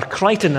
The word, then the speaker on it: Creighton: Bishop Creighton, or Crichton as his name may be pronounced.